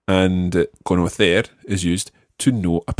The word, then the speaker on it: conocer: All the infinitives always are stressed on that last syllable. So, mm, so it's saber, conocer. – Saber, conocer.